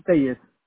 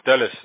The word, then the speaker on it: Tellez: It's Raul Tellez. – Spell it for me. – T-E-L-L-E-Z. – Oh, Tellez, okay.